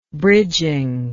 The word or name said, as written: bridging